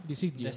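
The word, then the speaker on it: De Sciglio: Lo voy a pronunciar mal, seguramente. Eh, Siglio. – De Siglio. Siglio de Sí, pues de, momento Italia que ha sido la selección que ha generado más ocasiones de peligro.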